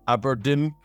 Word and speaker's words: abrdn: There was one, Standard Life Aberdeen became Aberdeen, I think. Like basically Aberdeen, but without the vowels after the initial A. – Yeah, A-B-R-D-N, it's spelled.